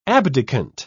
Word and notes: abdicant: British English

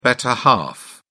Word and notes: better half: British English

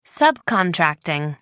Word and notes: subcontracting: American English

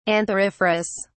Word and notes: antheriferous: British English

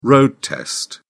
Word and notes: road test: British English

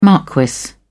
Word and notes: marquis: British English

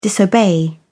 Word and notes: disobey: British English

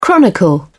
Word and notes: chronicle: British English

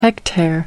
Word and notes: hectare: American English

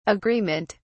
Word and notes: agreement: British English

